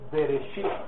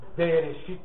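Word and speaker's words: Bereshit (בראשית): El nombre del libro en el idioma hebreo es Bereshit. Empieza con la letra así. B, la R, la letra Aleph, Bere, la letra Shin, la letra Yod y la letra Tau. Bereshit. Se escribe así.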